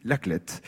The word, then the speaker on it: La Clayette: Alors ça s'écrit La Clayette, mais on prononce depuis toujours ici La Clète.